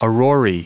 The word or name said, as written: aurorae